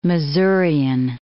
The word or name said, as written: Masurian